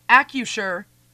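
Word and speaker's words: Acrisure: By the way, I've been told in my ear by Devin that it's AccuSure. It's a silent R.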